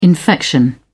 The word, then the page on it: infection: British English